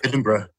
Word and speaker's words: Edinburgh: I went to Edinburgh. Is that how I pronounce it? And Edinburgh.